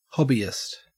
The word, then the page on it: hobbyist: Australian English